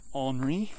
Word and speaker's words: Henri: So, of course, won't be as bad as the folks in New England who are looking down the barrel at Tropical Storm Henri, which sounds very much like the English word ornery, which doesn't sound like that's a mistake.